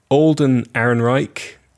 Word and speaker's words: Alden Ehrenreich: Alden Ehrenreich. Mm-hmm. That's how you say his name.